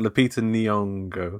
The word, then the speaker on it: Lupita Nyong'o: To be fair, I know it's got Lupita Nyong'o. Is that how you say her name? In it? – Yeah.